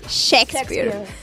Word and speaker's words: Shakespeare: Ya a continuación vamos a hablar sobre William Shakespeare. ¿Lo dije bien, chicas? – Shakespeare. Perfecto.